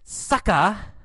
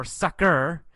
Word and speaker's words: sucker: And then sucka, or sucker, two pronunciations, sucker or sucka.